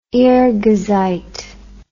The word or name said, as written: irghizite